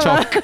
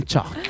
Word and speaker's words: choc: Choc. – choc. I need some chalk. No, it's choc, not chalk. Chalk, chalk, chocks, choc, chalky.